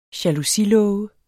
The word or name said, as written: jalousilåge